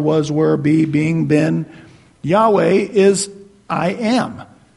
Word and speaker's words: Yahweh: Later, that gets shortened to just I am Yahweh, and it just in English, or we usually pronounce that Yahweh.